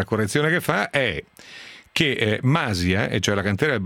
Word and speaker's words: Masia: Masia va con l'accento sulla I, perché sì, è Masia.